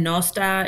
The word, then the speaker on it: nos da: Yeah, and nos da is good night. So da is good, and then bora is morning, nos is night. – Say good night then. – Nos da.